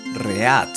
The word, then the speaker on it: read: Pero recuerden, el inglés es una lengua que no es fonética. Significa que no se pronuncia como se lee. Porque esta palabra se pronuncia read ¿Siguen? como si la E y la A se mezclaran y formaran una E.